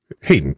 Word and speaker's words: Hayden: Do you say Hayden or Haydn? – Hayden. Haydn is the composer.